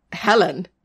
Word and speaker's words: Helen: Because in the sort of classic tale, Helen rhymes with Ellen.